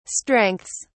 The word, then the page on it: strengths: British English